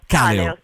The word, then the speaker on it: Kaleo: Bene, comunque ci fermiamo un attimo per la pubblicità, torniamo proprio con quindi la pronuncia qual è? Kaleo? Come si dovrebbe pronunciare? – Kaleo. – Molto più semplice in realtà.